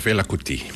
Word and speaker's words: Fela Kuti: Fela Kuti.